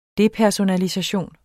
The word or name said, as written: depersonalisation